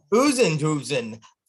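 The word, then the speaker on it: Oosthuizen: Over Usenthusen and Schwartzel. did I mispronounce his name? Probably I did. – Usenthusen.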